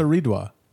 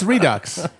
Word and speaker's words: Redux: It looks like the word redux. – It's redux. – It's redux. – Redux. – It's redux. – Oh, my family always pronounced it redois. – What the fuck is wrong with your people? It's a UX. Where's the O coming from? – I don't know.